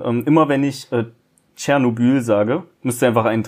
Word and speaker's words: Tschernobyl: Oder Tschernobyl. Es wird richtig Tschernobyl ausgesprochen. Ich habe nochmal nachgeguckt.